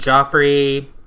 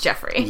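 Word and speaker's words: Geoffrey: We briefly mentioned Joffrey and Jeffrey Joffrey Jeffrey. Jeffrey. Ugh. It's Jeffrey. It's spelt weird, but it's pronounced Jeffrey.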